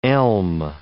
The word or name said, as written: elm